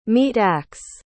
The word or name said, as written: meat-ax